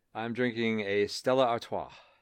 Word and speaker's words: Stella Artois: And you have to say it like that. – Exactly. – Or else it's like Stella Artois. And then it doesn't taste as good.